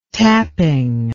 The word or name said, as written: tapping